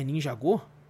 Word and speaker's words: Ninjago: Ninjago. É Ninjago? – É. – Mentira. Go. Eu falei errado a minha vida inteira. É, é Ninjago. Pra é Ninjago.